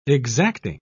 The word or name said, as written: exacting